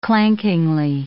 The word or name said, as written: clankingly